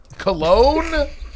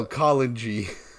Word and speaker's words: Cologne: Cologne? – You think I know these things? We're at the Gamescom. Oh, actually, I do know Gamescom. – I thought you knew how to pronounce Cologne. – Oh, Cologne.